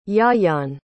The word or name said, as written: Ieuan